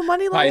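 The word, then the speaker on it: Muni Long: You don't know who Money Long is? The Hours and Hours. – That's how you pronounce her name? – Yes.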